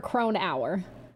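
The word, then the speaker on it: Cronauer: Like Kroner. – I was trying to... Okay, Kroner. – Let's try to remember Kronauer.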